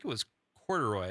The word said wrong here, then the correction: Couderay: Did you say Corduroy? – Am I saying that wrong? It's spelled phonetically. It looks like Corduroy. Corduroy? – Ends with an A. – Oh, no. Did I get Couderay. – Oh, is that how you say it?